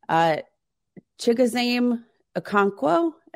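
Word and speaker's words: Chigoziem Okonkwo: Like, I don't think it's like the most preseason, regular season, Chigozem Okonkwo.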